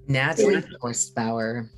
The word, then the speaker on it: Natalie Forstbauer: Natalie Forstbauer.